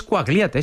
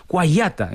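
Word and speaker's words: Quagliata: Bueno, a mí también me daba la brasa Javi Torres, nuestro compañero, por ejemplo, Cuagliata, no es Cuagliata, es Cuayata.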